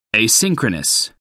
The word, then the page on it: asynchronous: American English